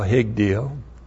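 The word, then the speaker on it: Higdil (הגדיל): You go right to left in Hebrew, but this is H I G D long I L Higdil.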